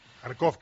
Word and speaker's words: Kharkov: Dice, parecerá increíble, pero en las calles de Kharkov se ven casi tantas camisetas de la selección española como tías buenas, y de estas hay unas cuantas. – Kharkov, qué bien pronunciado. Kharkov o Kharkiv.